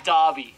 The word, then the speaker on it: Derby: It's spelled Derby, but it said Derby.